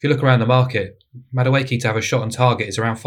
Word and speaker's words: Madueke: Is that how I say his name? – Madueke, yeah. Good player.